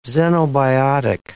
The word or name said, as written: xenobiotic